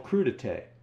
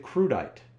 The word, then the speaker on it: crudité: I always knew they were called crudité, but if I saw it written down, I'd pronounce it crudite.